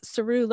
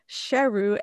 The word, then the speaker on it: Sheru: And then, you know, the way they get the name Lion for the movie is Saru learns that all these years he had been mispronouncing his own name, which was actually Sheru, S-H-E-R-U, which means lion.